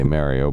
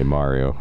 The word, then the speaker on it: Mario: So I say Mario, but you say Mario, and you're right. That's the actual way.